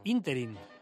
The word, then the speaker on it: ínterin: La palabra ínterin, que significa intervalo de tiempo que transcurre entre dos hechos, según la academia, es esdrújula, no aguda, no se pronuncia ínterin, como solemos hacer.